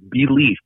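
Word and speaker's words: Be-Leaf: There's one from the Herzog line that's called Belief. Like you say, believe. – Right. – All right. This one is spelled B-E. And then dash L-E-A-F.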